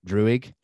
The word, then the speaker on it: Druig: Druig.